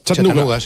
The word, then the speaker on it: Chattanooga: Así, ¿en Chattanooga? – Chattanooga, sí.